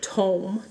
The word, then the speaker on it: tome: Galateri, and it's the tome, not tomb, tome.